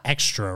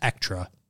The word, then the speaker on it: Ektra: It's not extra, right? Like, it's definitely pronounced Ektra.